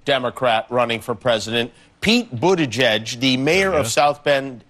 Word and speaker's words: Buttigieg: Buttigieg is a Maltese name. It means owner of chickens. – All right, so you pronounce it boot, B-O-O-T, edge, edge.